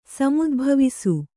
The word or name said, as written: ಸಮುದ್ಭವಿಸು